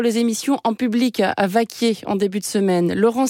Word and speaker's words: Vacquiers: Dernier jour à Vaquier. Au fait, Vaquier, ça s'écrit V-A-C-Q-U-I-E-R-S. Vaquier.